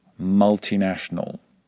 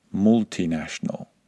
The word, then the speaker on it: multinational: And it's exactly the same with multinational, not multinational. In fact, any word that begins with multi should be pronounced with a short vowel sound if you're following these rules.